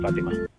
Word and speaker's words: Fatima: That would be A, on the Feast of Our Lady of Fatima.